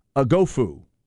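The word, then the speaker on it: Oghoufo: Outside linebacker slash D-end, however you want to look at it, Ovi Agofu from Notre Dame is going to be at Texas. We double-checked pronunciation. Lot simpler than it looks. Agofu.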